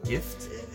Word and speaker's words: GIF: GIF, like gift? Apparently it's GIF. 생각 안 해봤어요? Not j it's a hard G.